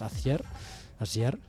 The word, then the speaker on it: Acier: Vamos a escuchar del que fue su último trabajo, publicado justamente en el año 83, Acier, se pronunciaría más o menos en francés, acero en castellano.